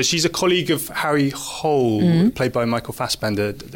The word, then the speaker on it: Hole: In the movie, it's just Hole, but I think the correct pronunciation in Norwegian is Hole.